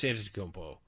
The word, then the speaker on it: Antetokounmpo: Giannis Antetokounmpo. – Say it again. – Giannis Antetokounmpo. – Giannis Antetokounmpo. – Antetokounmpo. There you go.